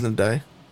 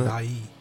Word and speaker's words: Ndiaye: Yeah, Ndiaye from Everton. Ndiaye. – Yeah, I just don't know how to pronounce his name. It is Ndiaye, isn't Ndiaye. – It? Ndiaye.